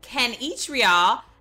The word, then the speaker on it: Kanichirayil: I have Dr. Jim Kanichirayil.